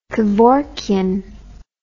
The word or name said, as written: kevorkian